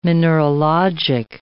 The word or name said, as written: mineralogic